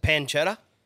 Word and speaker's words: pancetta: And then is it pancetta? – Yep.